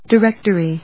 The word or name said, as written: directory